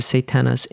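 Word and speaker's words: Satanas (Σατανᾶς): And Satan in Greek means Satanas. The way you pronounce it, Satanas, in Hebrew, it's Satan.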